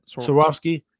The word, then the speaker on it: Swarovski: That's the right way to say it. I just Googled it. – Swarovski? – Yeah, you said it right. Okay. I've never known how to pronounce it, so I looked it up, but you got it right.